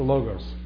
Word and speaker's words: logos: Proper pronunciation for logos.